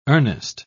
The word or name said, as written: earnest